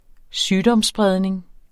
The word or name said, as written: sygdomsspredning